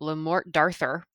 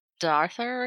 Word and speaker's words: Le Morte d'Arthur: Le Morte d'Arthur or something? – D'Arthur. It was actually D'Arthur. It was a lady with an English accent, so I assume she knew what she was talking about.